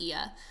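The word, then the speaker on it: Oia: It's O-I-A, mm-hmm. I think it's pronounced Ia, which is kind of the classic like sunset picture where you see all the postcards from Santorini.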